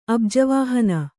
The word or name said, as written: ಅಬ್ಜವಾಹನ